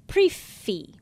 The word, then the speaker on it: prix fixe: And one of the ones I had a question about was prefi. – Prefi, P-R-I-X-F-I-X-E. – Right. So why is the first X silent in pre?